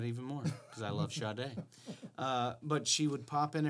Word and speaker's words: Sade: I love Sade, not Sade. – Well, it's spelled S A D E. – It's Sade, and she's awesome.